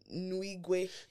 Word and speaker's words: Nwigwe: Toby Nwewe. I'm using wow. You're Nigerian. So how do you say it? – Nwigwe. – So wow. I butchered that.